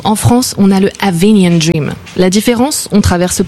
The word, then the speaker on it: Avignon: Mais j'ai remarqué, vous prononcez tous mal, en fait. Vous dites Avignon, alors que ça se dit Avignon.